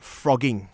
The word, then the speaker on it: phrogging: So just a moment ago, you were talking about frogging. – Frogging. Now, this is spelled P-H-R-O-G-G-I-N-G. – Right. – But it does come from the term frogs.